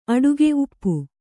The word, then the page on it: ಊಪು: kannada